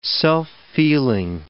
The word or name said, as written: self-feeling